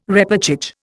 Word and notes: repêchage: Indian

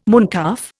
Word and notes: mooncalf: Indian

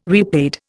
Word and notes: repaid: Indian

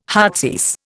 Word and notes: heartsease: Indian

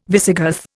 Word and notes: Visigoth: Indian

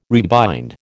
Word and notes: rebind: American English

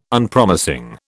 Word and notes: Unpromising: British English

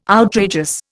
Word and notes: outrageous: Indian

